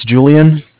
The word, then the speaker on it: Julian: Yeah, it's Julian, J-U-L-I-A-N.